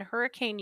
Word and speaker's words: Hurricane: And it's hurricane. – Hurricane, that's right. – That's so how they say it. I don't know why. – Right, it looks like hurricane, but okay.